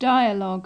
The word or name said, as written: dialogue